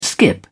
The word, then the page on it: skip: British English